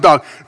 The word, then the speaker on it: Doeg: And remember Dog, D-O-E-G in English, but it's pronounced Dog.